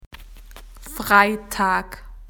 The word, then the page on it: Friday: Australian English